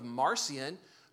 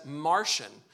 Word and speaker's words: Marcion: I'm going to show you a man by the name of Marcion, who you can also pronounce as Martian.